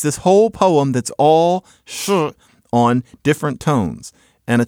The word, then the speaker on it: shi: It's this one syllable, shi. You can imagine it's spelled S-H-I and it's pronounced shi. You can pronounce shi on four different tones. You can go shi, shi, shi, or shi.